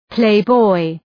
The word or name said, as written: playboy